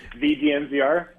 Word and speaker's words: the DNVR: DNVR, you put the T-H-E in front of that. How do you pronounce it? – What do you mean? The DNVR? – It's the DNVR.